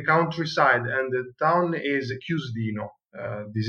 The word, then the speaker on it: Chiusdino: Chiusdino. – Yeah. It's a good pronunciation.